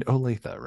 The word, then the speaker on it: Olathe: And you are in Olathe, right? Is that, did I pronounce that right? Okay.